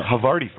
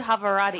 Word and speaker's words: Havarti: So no, Harvardi. – No, I call it Havarati. – Havarati. – Oh, Havarati. – Like a Maserati.